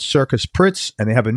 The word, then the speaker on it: Cirkus: It's spelt C-I-R-K-U-S, Circus in Sweden, Pritz, and it's P-R-U-T-Z. But if you just look up C-I-R-K-U-S, I'm pretty sure you'll get Circus Pritz coming up.